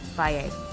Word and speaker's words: Fage: There's many brands, but Faye is sort of the best one. Again, that's spelled F-A-G-E, Faye. You